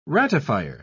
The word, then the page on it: ratifier: British English